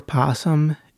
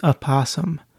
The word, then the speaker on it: opossum: It's not possums or possum. It's opossum. So, yeah, that word begins with an O, not a P. But I read that a lot of people in North America, they pronounce it possum. They don't say opossum. But it is opossum with an O in the beginning.